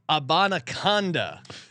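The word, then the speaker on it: Abanikanda: Sean, here to help you pronouncing Izzy A on the Jets. It's Israel Abanaconda.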